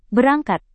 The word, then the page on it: berangkat: indonesian